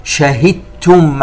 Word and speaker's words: شهدتم: كلمة شهدتم، ننتبه إلى الدال الساكنة، حيثما أتى بعدها حرف التاء فإن هذه الدال تدغم فيها إدغاما كاملا، أي تحذف الدال وتبقى التاء. شهدتم علينا.